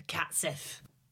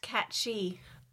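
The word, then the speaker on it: cat sìth: Ah, the cat Sith. – Actually, it's Gaelic and it's pronounced cat-she.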